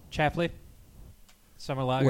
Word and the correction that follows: Schlafly: Wait, pronounce that again. – Chafley. – Chafley. – Schlafly. Ah. I like shrimp with my Chafley.